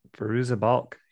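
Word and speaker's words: Fairuza Balk: Fariza Balk, is that it? Is that how you say her name? She was in like The Craft. – Yep. – I think. – She was in The Craft. Mm-hmm. Fariza Balk, you're right.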